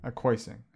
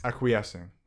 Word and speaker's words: acquiescing: Acquiescing? – Acquiescing. Yeah, let me pull it up quick. This is how you pronounce it. – Acquiesce. – Acquiescing.